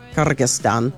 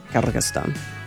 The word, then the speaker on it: Kyrgyzstan: As a result of that, Kazakhstan, Uzbekistan, Tajikistan, Kyrgyzstan. Kyrgyzstan. Answers from the stuns. He's trying to teach me how to say these names properly. Anyway, they've all implemented quarantine controls at the state border crossings, which effectively means they've kind of shut the borders.